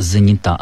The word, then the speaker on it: занята: Аудитория или мама занята, а не занята. В кратком прилагательном, в женском роде ударение падает на окончание.